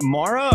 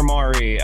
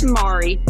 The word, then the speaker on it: Mari: Is it Mara or Mari? I don't want to mispronounce it. – It's Mari.